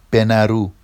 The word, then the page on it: benaru: Vafsi